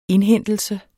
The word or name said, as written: indhentelse